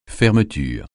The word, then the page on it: fermeture: francais